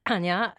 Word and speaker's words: Anna: Well, my name is Anya. You spell it A-N-N-A.